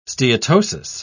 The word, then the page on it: steatosis: British English